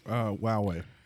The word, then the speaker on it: Huawei: Huawei.